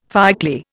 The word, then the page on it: fireclay: Indian